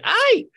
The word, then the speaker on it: ¡Ay!: Hey, tiene signos de exclamación, así es que se dice ¡ay! – Sí, es la manera correcta de pronunciarlo, efectivamente.